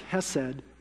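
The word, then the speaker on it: Hesed (חסד): So it looks like hesed, but if you're going to say it right, you got to say it like you got a goober in your throat. Hesed.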